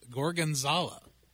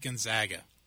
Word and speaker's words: Gorgonzola: And one of my favorite things was, and I finally learned how to pronounce it after like four times, Gorgonzola. And I would always call it, yeah, I'll take the steak Gonzaga.